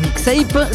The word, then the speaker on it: Mixape: Et sur le Discogs de Mixape, ça s'écrit M-I-X-A-P-E.